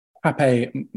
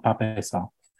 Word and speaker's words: Pape: And also I saw a Ghanaian fan who was saying that Pape Matasar, the it's pronounced Pap, apparently. So there you go. That's something I learned. It's apparently it's Pap Saar, not Pape Saar, which is interesting.